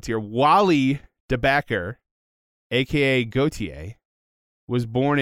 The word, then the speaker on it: Gotye: In French, that's Gotye, although it's spelled very differently.